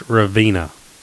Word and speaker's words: Ravenna: I think it says Ravenna when we get off the exit. – Yeah, it's pronounced Ravina. Go ahead. – Ravina. Mm. I know. I still don't get that right.